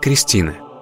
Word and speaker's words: Христина: В современном русском языке имя Христина обычно произносится как Кристина.